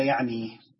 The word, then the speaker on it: يعنيه: يعنيه. قال: حديث حسن، رواه الترمذي وغيره هكذا. والأصوب في نطق هذه الكلمة أن تقولها هكذا، يعني ممكن تمشي يعني زي ما نزل، لكن الأصل نقولها يعني بفتح الياء.